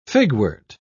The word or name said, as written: figwort